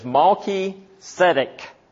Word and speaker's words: Melchizedek: Melchizedek really is two Hebrew words. And in English, we would spell it, or we would pronounce it as Malki-Zedek.